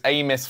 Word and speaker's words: Amis: It's so it's Amis phonetically.